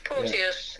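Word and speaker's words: Porteous: But the January window, and it's all gone quiet already on Ryan Portios, is it? Portiolios? I can't say his name. – Portios.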